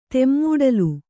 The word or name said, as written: ತೆಮ್ಮೂಡಲು